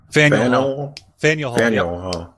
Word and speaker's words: Faneuil Hall: I went to Faneuil Hall. Is that how you say it? Is that the pronunciation? – Faneuil Hall. Right.